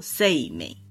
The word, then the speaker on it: 晴明: 他的名字这个晴明呢，我们会念作 Say 美。这个是音读，那日文里面应该还有训读的读法，念作哈鲁阿基或者是哈雷阿基拉之类的。到底是怎么念呢？现在已经无从考证了。